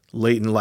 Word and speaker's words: Leighton: Leighton, like, rhymes with Satan.